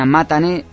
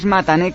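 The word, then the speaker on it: Matané (またね): Así como suena Matané, y nosotros pues a veces lo pronunciamos un poco difícil, ¿no? Es Matané como suena, no con dos T's, sino con una.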